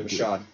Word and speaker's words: Michaud: Michaud. – Michaud. – Yes. Michaud. Whatever. – Is it really French? – It's Michaud on the East Coast. In France, it's Michaud, and then Michaud.